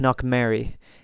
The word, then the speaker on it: Knockmary: So I think it's pronounced Knockmary. Now, if you're really going to go authentic with it, it'd be Knockmaira.